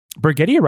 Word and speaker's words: Brigetti: And Brigetti rhymes with spaghetti.